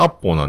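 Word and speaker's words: Apple: で、やっとアップルが、えー、反映されたんで、えー、アップルといえば、えー、ネイティブ的な発音すればアッポーなんで、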